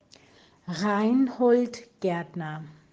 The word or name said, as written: Reinhold Gaertner